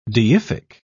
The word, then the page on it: deific: British English